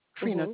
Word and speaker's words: Treantia: T-R-E-A-N-T-I-A. – Yes, ma'am. – Pronounce that first name for me. – It's just pronounced Trina. – Trina mm-hmm.